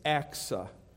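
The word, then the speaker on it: Achsah: I had to look that up online, how to pronounce that. It's Aksa. And Othniel, the son of Kenaz, the brother of Caleb, captured it.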